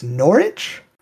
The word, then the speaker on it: Norwich: One of the oldest family curses I could find was that of Lord Sevenwall of, I think it's Norwich, right? – Yes, that is how you pronounce that place.